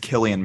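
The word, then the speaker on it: Cillian: I believe it's Killian Murphy.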